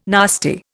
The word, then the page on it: nasty: Indian